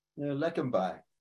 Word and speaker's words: Leqembi: How do you pronounce that? Leqembi? – Leqembi. – Leqembi.